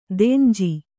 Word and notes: ದೆಂಜಿ: denji

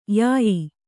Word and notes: ಯಾಯಿ: Kannada

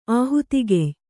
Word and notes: ಆಹುತಿಗೆಯ್: āhutigey

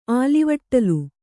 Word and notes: ಆಲಿವಟ್ಟಲು: ālivaṭṭalu

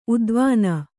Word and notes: ಉದ್ವಾನ: udvāna

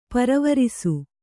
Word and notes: ಪರಿವರಿಸು: Kannada

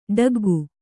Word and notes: ಠಕ್ಕಿಕ್ಕು: ṭhakkikku

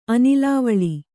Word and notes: ಅನಿಲಾವಳಿ: anilāvaḷi

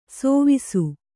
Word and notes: ಸೋವಿಸು: sōvisu

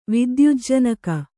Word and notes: ವಿದ್ಯುಜ್ಜನಕ: vidyujjanaka

